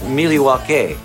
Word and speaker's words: Milwaukee: Actually, it's pronounced Miliwaukee, which is Algonquin for the good land.